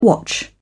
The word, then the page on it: watch out: British English